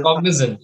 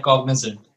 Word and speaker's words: Cognizant: I guess that when Aston Martin announced that they have a brand new title sponsor in Cognizant. Pardon me if I'm pronouncing it wrong. That is Cognizant. Yeah, it's correct. Cognizant.